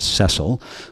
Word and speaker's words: Cecil: Before Plager, the most notable Jewish NHL coach was Cecil Hart, probably pronounced Cecil, who led the Montreal Canadiens for 9 seasons in the 20s and 30s and won back-to-back Stanley Cup titles in 1930 and 31.